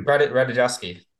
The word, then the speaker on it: Ratajkowski: Ratajkowski.